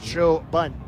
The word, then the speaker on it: Choban: His last name is spelled C-H-O-B-A-N, pronounced Chobun.